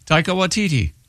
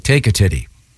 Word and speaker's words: Taika Waititi: And pronounce it again, Taika? – Taika Waititi. – Okay. I thought it was like take a titty.